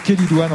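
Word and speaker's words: chélidoine: La Quélidoine, ça s'écrit CH au début, ça se prononce Quélidoine en général.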